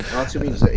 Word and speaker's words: Otivm: Otivm is the Latin pronunciation. So the TI is a Z, so Otivm is the right Latin pronunciation, because it's a Latin word, as Barbara will explain.